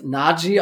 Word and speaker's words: Najee: Zum Beispiel Najee, das hat er erklärt, das wird Najee ausgesprochen, war ihm ganz wichtig.